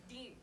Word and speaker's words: Dee: D. – D, just D. – Just D. – D-E-E.